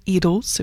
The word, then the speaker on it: Idylls: Geoffrey Tate led the English Chamber Orchestra in George Butterworth's Two English Idylls, or Idylls, if you want to go with the British pronunciation, for small orchestra.